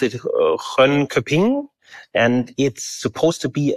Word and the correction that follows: Grönköping: Yeah, Grönköping, or how we now pronounce it,